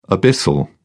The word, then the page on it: abyssal: British English